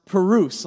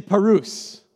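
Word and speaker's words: praus: The adjective form of perutes is the word gentle, which is pronounced perus. Let me hear you say perus.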